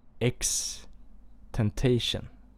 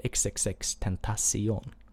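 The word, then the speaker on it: XXXTentacion: Och han sa X Temptation. Vet du vem det är? Och eh, jag, Johan och André, vi började asgarva eftersom han uttalade namnet ganska så fel. Han heter ju XXX Tentacion.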